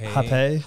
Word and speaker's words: Hoppe: It is French. Hoppe. – Hoppe.